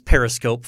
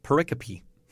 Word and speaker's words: pericope: So it's not uncommon for me to read a paper, someone trying to use that word, and I like, this periscope follows the initial introduction. Like, what, periscope? Wait, what? What? Oh, yeah. – I don't know if I've ever heard that word actually pronounced. – Pericope.